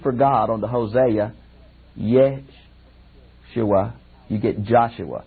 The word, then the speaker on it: Yeshua: Now, the word Jesus really is pronounced in Hebrew, Yeshua.